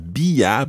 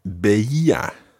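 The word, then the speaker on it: veía: So it's not bia, but beia. – Beia. – Yeah, it's another one of those see how many vowel sounds you can fit into the one word. Beia. – Beia. – So it goes beia, beias, beia. – Beia. Beias, beia. – Yeah, with the stress each time on the E.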